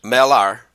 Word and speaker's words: Mälaren: L-A-R provinces? How do you pronounce that? – That's Mälaren. Mälaren.